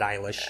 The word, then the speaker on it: Eilish: When We All Fall Asleep, Where Do We Go by Billie Eilish. I hope that's how I pronounce her name. – Yeah, that's how I've heard it, Eilish.